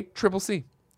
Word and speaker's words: ACCC: Uh, Mark says ACCC is widely accepted to be pronounced as a triple C.